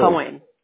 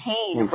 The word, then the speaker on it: Koehn: It's Kane, K-O-E-H-N. – Okay. And you pronounce it Kane, right? – That's right.